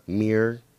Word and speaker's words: Meir: Meir.